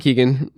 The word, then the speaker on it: Keegan: I'm Bitcoin Keegan, basically everywhere on the internet. – And that's key spelled Keegan, key spelled with K-E-E, two E's, not E-A.